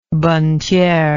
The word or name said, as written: bonnetiere